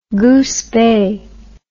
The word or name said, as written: goosebay